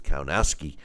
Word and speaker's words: Kownacki: If you recall, he was brutally knocked out by Deontay Wilder and then stepped in the ring with Adam Kownacki or the way they pronounce it is Kownacki, and was stopped there.